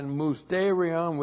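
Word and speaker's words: mousterion (μυστήριον): Looks like that in the Greek, M-U-S-T, long E-R-I-O-N, mousterion.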